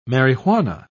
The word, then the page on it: marihuana: British English